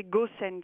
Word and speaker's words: Gosens: Alors, c'est Gosens. – Gosens. Ah, oh, ben c'est oui, ça. Effectivement, je vois pas pourquoi est-ce qu'on prononcerait deux O à l'anglaise. – Ah, ben oui, Gosens. C'est un nom flamand, alors ça se prononce à la flamande.